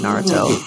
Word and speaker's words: Naruto: I saw Naruto when I was in like middle school. – I'm mad it. You said that in 4.5 syllables instead of just saying Naruto.